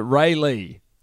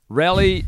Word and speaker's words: Raleigh: Who the fuck pronounces it Ray Lee? Raleigh, North Carolina is said like trolley without the T.